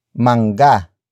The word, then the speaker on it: mangga: which in this case is mangga. Mangga. Mangga. Mangga is spelled as M-A-N-G-G-A.